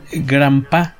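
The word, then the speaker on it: Grampá: la eh, G cómo se pronuncia? Grampa.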